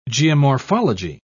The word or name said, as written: geomorphology